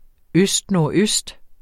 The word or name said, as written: østnordøst